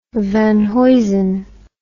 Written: Van Heusen

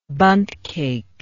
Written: bundt cake

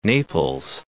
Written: Naples

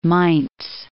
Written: Mainz

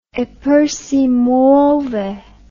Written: eppur si muove

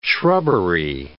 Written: shrubbery

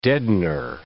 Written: deadener

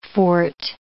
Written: fort

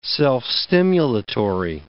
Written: self-stimulatory

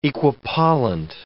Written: equipollent

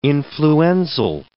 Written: influenzal

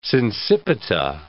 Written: sincipita

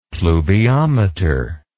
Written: pluviometer

